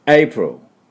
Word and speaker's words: April: April というのはしっかり発音しません。一瞬だけ。 April みたいな。で、 April とも言ってはいけない。最後にウをつけてもいけません。 L で終わるだけです。で、 Real。